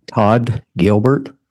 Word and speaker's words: Todd Gilbert: Todd Gilbert. T-O-D-D-G-I-L-B-E-R-T.